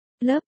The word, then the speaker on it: LRP: LRP, phát âm là, lớp là những người lính trong lực lượng viễn thám Hoa Kỳ.